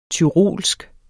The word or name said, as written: tyrolsk